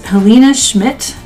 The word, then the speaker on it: Helena Schmitt: This guest, Helena Schmitt.